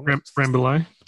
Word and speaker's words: Rambouillet: beyond Rambouillet. I said that wrong. – Rambouillet.